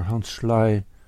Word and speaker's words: Selye: As Hans Selye, or Hans Schley, as it might be pronounced, described in his story of the physiology, the electrophysiology.